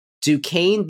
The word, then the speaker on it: Duquesne: D-U-Q-U-E-S-N-E. It's Duquesne is how you say that university's name I have learned recently.